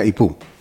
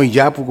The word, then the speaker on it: Ipú: Me dijo, se pronuncia Ipú. No Iyapu, como usted le dijo.